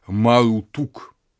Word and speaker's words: Мардук: Слово «Мардук» или на западно-семитский манер «Меродах», видимо, в полной форме читается как «Марутук».